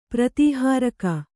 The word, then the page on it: ಪ್ರತಿಹಾರಕ: kannada